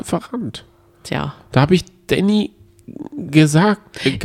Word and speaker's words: Danny: Naja, Danny, das äh, schreibt man D-A-N-N-Y, spricht aber Englisch aus Danny.